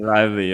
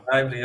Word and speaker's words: Lively: Und spielt von der Blake Lively und der Film, oder wie ist das, kann ich es aussprechen? – Lively, ja. – Lively, ja.